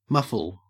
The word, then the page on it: muffle: Australian English